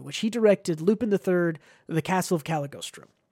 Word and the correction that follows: Cagliostro: Cagliostro. I'm going to say that right. That's a weird one.